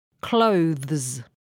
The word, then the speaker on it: clothes: 第二个是浊辅音 z，在发这个音的时候声带也需要震动，所以 THES 就读成 thes， clothes。